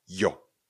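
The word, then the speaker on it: yo: tak ho vyslovujeme jako J. Je to písmeno se tedy jo. Když uvidíme Y, O, jo. Jo.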